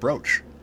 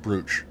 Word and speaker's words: brooch: It's where I learned the word brooch. Although I pronounced it brooch for a very long time because I just read that word.